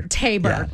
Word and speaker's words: Tabor: Matthew Tabor. Am I saying that right? Tabor? – Tabor. – Yeah, okay. Yeah. I was like, he's from Louisiana. It could be Tabor.